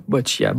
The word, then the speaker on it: boccia: C'est quoi le boccia? Boccia? Est-ce que je le prononce comme il faut? – Oui, c'est bien boccia.